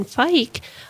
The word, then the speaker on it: Fike: We're also being sponsored by Bruce and Eileen Fike. Rhymes with bike, they say.